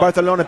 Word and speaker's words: Barcelona: Say it correctly. – That is how you say it. That's how Barcelona people say it in Barcelona.